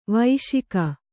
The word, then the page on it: ವೈಶಿಕ: vaiśika